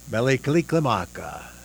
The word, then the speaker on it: Mele Kalikimaka: Mele Kalikimaka. Mele Kalikimaka is the thing to say on a bright Hawaiian Christmas day.